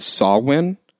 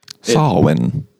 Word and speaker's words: Samhain: So it's actually pronounced, it's spelled Samhain, but it's pronounced Samhain. – Samhain.